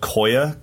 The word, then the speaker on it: Koia: This is called Koia, K-O-I-A.